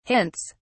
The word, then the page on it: hints: British English